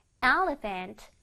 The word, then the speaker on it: elephant: Elephant. Elephant. 요번에도 이가 빨간색으로 되어 있네. 자, 요번에 이는요, 좀 길게 발음해 줄 거야. 코끼리가 조금 느리잖아요. 이를 조금 코끼리라고 생각하고 느리게 해주세요. Elephant.